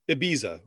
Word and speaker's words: Ibiza: So Spanish eyes are pronounced like E, right? So it's Ibiza, right? – Yes.